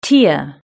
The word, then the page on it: tear: British English